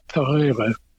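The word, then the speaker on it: Pereire: And my favorite rose is one that in that group, and it's called Madame Isaac Pereira. It has a somewhat complex history. But Madame Isaac Pereir is usually how you hear it pronounced here.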